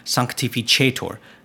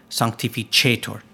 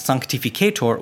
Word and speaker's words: sanctificetur: So the Paternoster, I was pronouncing Sanctificetur incorrectly, What I just said is the correct pronunciation, the ecclesiastical Latin pronunciation, sanctificetur, sanctificetur, ch, as in chair. I was pronouncing it in the original recording as sanctificetur, which is a classical Latin, that hard C, the k sound, like cup.